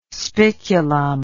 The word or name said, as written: spiculum